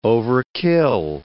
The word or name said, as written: overkill